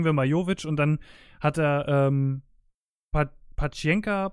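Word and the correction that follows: Paciência: Pacienka, wie würde, er, Paciencia, wie würde er ausgesprochen? – Paciencia.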